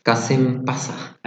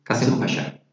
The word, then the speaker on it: Kasımpaşa: Moving on to the next pronunciation is another club from Istanbul, Kasımpaşa. – Another team, another behind them, there is a big company. – Did I say it right firstly? – Kasımpaşa.